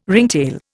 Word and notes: ringtail: Indian

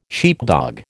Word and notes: sheepdog: American English

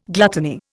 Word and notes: gluttony: Indian

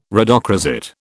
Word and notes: rhodochrosite: British English

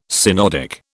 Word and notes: synodic: British English

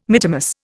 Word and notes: mittimus: Indian

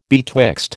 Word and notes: betwixt: American English

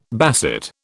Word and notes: basset: British English

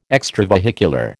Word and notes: extravehicular: American English

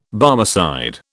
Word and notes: Barmecide: British English

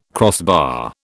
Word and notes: crossbar: British English

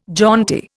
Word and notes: jaunty: Indian

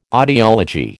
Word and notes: audiology: American English

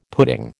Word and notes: pudding: American English

